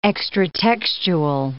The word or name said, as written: extratextual